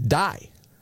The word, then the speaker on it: Dai: Dai. I am saying that right, correct? – I believe D-A-I. – So. – D-A-I.